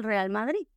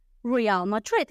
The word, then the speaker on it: Real Madrid: My favorite team is Real Madrid. Y aunque yo diga Real Madrid en inglés, pues tengo que cambiar la pronunciación a la R suavecita. No puedo decir my favorite team is Real Madrid.